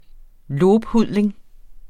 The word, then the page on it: lobhudling: ˈloːbˌhuðˀleŋ; -ˌhuðleŋ; ˈlob-